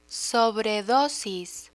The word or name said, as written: sobredosis